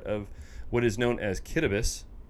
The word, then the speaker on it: Kiribati: Kiribati, as it's spelled, Kitabus, as it's pronounced.